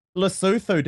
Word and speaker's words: Lesotho: Lesotho, Daniel, is that the correct pronunciation of the Southern African nation of that name? – Yes.